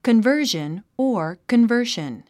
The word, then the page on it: conversion: kənvə́ːrʒən; コンバージョン